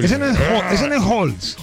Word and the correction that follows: Hulk: Hulk. – ¿Por qué? Pues, digo, Hulk, que Hulk, Hulk. – ¿Y cómo se dice? – Hulk. – Y no se dice Halls.